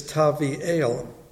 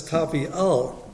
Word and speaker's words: Tavi'el: But what Isaiah likes to do is play a lot of word games, because the normal way to pronounce Tabeel's name in Hebrew is Tavi'el. Tavi'el, meaning God is good. But he makes a slight change and he pronounces it as Tavi'al. And Tavi'al means good for nothing.